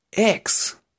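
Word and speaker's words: eggs: El primero, el que no puede faltar, huevos, que en inglés sería ex, Ex, así como cuando hablamos de nuestra ex novia, ex, se pronuncia igual, pero se escribe muy diferente, porque se escribe así.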